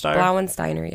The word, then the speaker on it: Blauensteiner: Elfried Blaunsteiner? – Blauensteiner, yeah. – Is that Elfried? Is that just supposed to be Elfried? Elfried? It's a female. – I think it's Elfried. – Elfried Blauensteiner, who was accused and convicted of poisoning 7 elderly men, one including a previous husband.